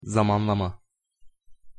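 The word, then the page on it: zamanlama: Turkish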